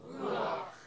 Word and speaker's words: Ruach: Ruach. – Say it like this. Ruach. – Ruach. – Sounds like you're getting ready to spit. Ruach.